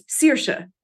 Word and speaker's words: Saoirse: Don't try to say, you know, if you say Saoirse instead of Saoirse, George Lucas, it's Saoirse, means freedom in Irish. That's how you say it.